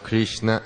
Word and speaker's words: Krishna: If you want to mispronounce it, you can say Krishna, okay? Krishna. – Si quieres pronunciarlo mal, mal puedes decirle así como lo hizo él. – There's no such Sanskrit. It's not a Sanskrit word.